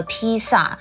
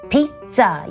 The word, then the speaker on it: pizza: 还有 pizza，可是发音不像我们中文的 pizza，它是 pizza，有没有听到那样子的一种意大利文的 feel 呢？